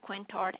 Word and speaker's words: Quintard: Okay, the next location is at Care Plus, located in Anniston, and the address is 700 Quinter Avenue. It's spelled Q-U-I-N as in Nancy, T as in Tom, A-R-D as in David Avenue. – Okay, 700 Quintard Avenue.